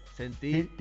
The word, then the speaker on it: Sentir: I know it's, wait, let me try. It's Center. – Sentir tu cuerpo.